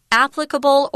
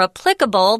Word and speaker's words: applicable: Applicable or applicable, both of those pronunciations are correct. Pick the one you like. Applicable, applicable. I think I say applicable more.